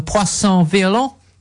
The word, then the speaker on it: Poisson Violent: Poisson Violent. – That's very good. That's absolutely spot on.